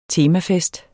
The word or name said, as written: temafest